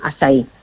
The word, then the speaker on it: açaí: Si lo queréis buscar escrito, que se escribe A con la cedilla, como si fuera la portuguesa, y se llama eso, acai orgánico, y se pronuncia açaí.